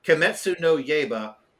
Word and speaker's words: Kimetsu no Yaiba: Kimetsu no Yaiba, and Kimetsu no Yaiba means Blade of Demon Destruction.